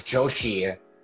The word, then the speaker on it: Joshi: For the audience, for context, Anshul's last name is Joshi. Yes. J-O-S-H-I, so it, you know, it rhymes with Yoshi, so I guess you implemented that as sort of in your campaign.